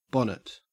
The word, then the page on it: bonnet: Australian English